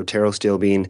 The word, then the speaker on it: pterostilbene: So it's there's a silent P on the front. – Exactly. – So PTERO STILLBENE. So taro steel bean.